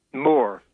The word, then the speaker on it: Mohr: Is M-O-H-R? Is it Maurer? – Moore. – Moore. All right. – Yeah. – A little different spelling than Roy Moore, but it's Moore nevertheless. – Yeah. It's pronounced the same way, I think, but just spelled differently.